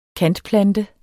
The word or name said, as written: kantplante